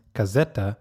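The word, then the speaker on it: Caseta: I think it's pronounced Casetta, and it's probably Spanish. And if my Spanish is correct, it probably means like house.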